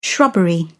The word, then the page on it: shrubbery: British English